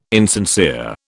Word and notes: insincere: British English

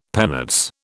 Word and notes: penates: British English

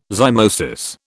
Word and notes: zymosis: British English

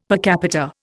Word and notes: per capita: Indian

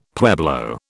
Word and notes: pueblo: British English

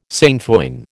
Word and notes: sainfoin: American English

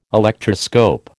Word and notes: Electroscope: American English

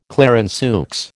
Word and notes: Clarenceux: American English

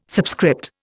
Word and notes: subscript: Indian

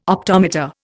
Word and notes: optometer: Indian

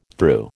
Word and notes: threw: American English

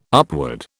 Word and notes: upward: British English

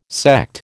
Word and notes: Sekt: American English

